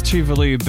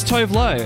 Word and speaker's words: Tuvalu: Apparently it is pronounced Tuvalu, but for years people would just see it and go, oh, it's Tove Lo.